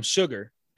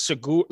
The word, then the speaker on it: Chigurh: No, the reason I remember the pronunciation is because he kept calling him Sugar. in the movie. But it was Shigar, like S-H-U-G-A-R, I think is how it was spelled. – Yeah, C-H-I-G-U-R-A.